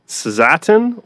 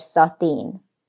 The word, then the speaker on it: Szatén: Pannonia Zatin or Pannonia Zatin.